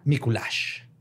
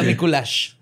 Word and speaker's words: Miculax: Hoy les voy a contar la historia de José María Mikuláš. – Mikuláš. – Mikuláš. Se escribe Mikulax. Mikuláš. – L-A-X. – Mikulax. perro. no, se pronuncia Mikuláš.